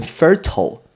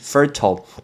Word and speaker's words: fertile: 我们就在讲这个字肥沃，肥沃这个字念成 fertile fertile f e r t i l e fertile，但是也有人会念成 fertile 都可以 f e r t i l e 这个字 fertile。